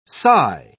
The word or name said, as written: cy